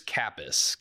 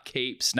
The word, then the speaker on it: Kappes: It's Lewis Kappas. Kappas, not capes, not caps. Lewis Kappas.